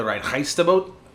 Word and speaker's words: Reinheitsgebot: And technically, um, they must be brewed within the city limits of Munich and be brewed with accordance of the Reinheitsgebot, Am I saying that right? Yep, hmm. – close I think enough. I, I think I got the phlegm right.